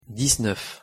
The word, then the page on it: dix-neuf: French